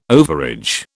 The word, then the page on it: overage: British English